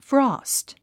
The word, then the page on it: frost: frɔ́ːst; フロースト